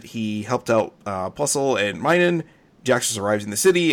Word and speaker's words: Minun: Minun,